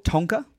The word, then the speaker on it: Tonka: 4 Tonka. – Excuse me? – It's Indian. – How do you spell that? – T-O-N-K-A. – Tonka. – No, Tonka. – Heard Tonka.